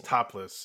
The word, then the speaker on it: Taplas: TAPLAS. Oh. Topless is how they pronounce it.